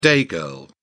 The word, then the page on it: day girl: British English